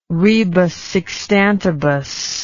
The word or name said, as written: rebus sic stantibus